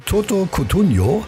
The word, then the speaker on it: Toto Cutugno: So, und am allerliebsten, schreibt die Corinna, hört sie von Toto Cutugno.